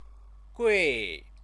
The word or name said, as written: gui